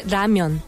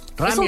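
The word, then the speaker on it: ramyeon (라면): Bueno, puede decir ramen si es japonés y el coreano se dice ramyeon. – Ramyeon.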